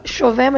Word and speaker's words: Chauvet: Bom, a minha rede social eu vou soletrar porque é um pouquinho difícil o sobrenome, mas é Ana C-H-A-U-V-E-T. Se fala Chauvert, mas se escreve Chauvet. C-H-A-U-V-E-T.